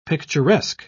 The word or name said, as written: picturesque